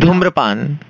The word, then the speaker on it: धूम्रपान: Dhun Praman, Sehat ke liye. Dhun Praman or whatever Prabhan is. Sehat ke liye thoda. – Dhumrapan, say it again. – मुझे वो प्रनाउंस नहीं करना तो बहुत खतरनाक. धूम्रपान.